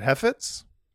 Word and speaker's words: Heifetz: We had you guys had Iris Heifetz. How do you pronounce that? Heifetz? – Yeah, I'd say yeah, okay. You nailed it.